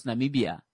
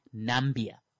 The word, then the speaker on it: Namibia: When Donald Trump masters how to pronounce Namibia, I will give a crap. Because I know homeboy just went with Nambia.